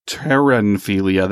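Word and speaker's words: pteronophilia: Last one that we want to talk about here is terenphilia. That's with a silent P because P should be silent. And pteranophilia is arousal to being tickled by feathers, specifically by feathers.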